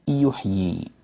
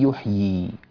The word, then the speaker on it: يحيي: في قوله تعالى الذي يحيي، هاتان الياءان الآن، هذه الياء الأولى ياء مدية والياء الثانية ياء متحركة، فأحيانا بعض غير المتقنين إذا أراد أن ينطق يدغم الياء الأولى في الياء الثانية، وهذا غير صحيح، فيقول ربي الذي يحيي الذي يحيي، لا يبين زمن الياء ولا يمكنها ويعطيها قدرها من الحركتين الكاملتين، فتندغم الياء الأولى في الثانية، وهذا إشكال عظيم، تدخل الياء المادية في الياء المتحركة وهذا غير مقبول عند القراء.